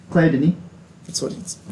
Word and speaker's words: Claire Denis: By Claire Denis. – Can you pronounce that for us? I know you're the French kid. – No. – The director's name. – Claire Denis.